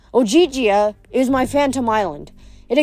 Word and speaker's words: Ogygia: You're in Ogygia." She pronounced it like Ogygia.